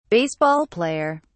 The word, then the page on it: baseball player: British English